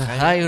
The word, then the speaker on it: Chai: Did you just say that like the High Riders? – The High Riders. I'm going to have to look them up. Oh, spelled like chai. – Spelled like chai, but pronounced high, which means life, of course.